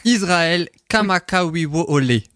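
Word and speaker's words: Israël Kamakawiwo Ole (Israel Kamakawiwoʻole): Ah, Alors si je le prononce correctement, Israël Kamakawiwo Ole.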